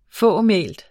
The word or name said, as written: fåmælt